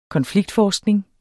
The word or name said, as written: KONFLIKTFORSKNING